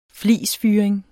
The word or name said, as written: flisfyring